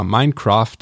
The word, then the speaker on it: Minecraft: Ähm, ja, Minecraft meines Erachtens ist die richtige Aussprache.